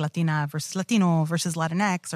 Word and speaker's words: Latinx: Which reminds me kind of like Tinkerbell, but that's fine. – Yeah. Yeah. – No, that's how I'll remember to pronounce it that way. With a little like Tinkerbell. Latinx.